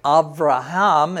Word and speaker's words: Avraham: So if we say Avram, Avraham, as we would say it in Hebrew, we pronounce that as a soft B, and a soft B is a V or a V sound.